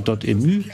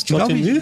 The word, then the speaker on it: Dotemu: Also es ist einmal mehr ein Spiel, das stammt von diesem französischen Publisher Dotemu, die auch so Sachen wie das Streets of Rage. Spricht man das so aus? – Dotemu? Ich glaube ja.